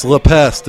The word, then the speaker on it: La Peste: It's La Peste. It's L-E-P-E-S-T-E.